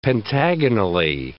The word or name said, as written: pentagonally